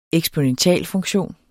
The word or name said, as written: eksponentialfunktion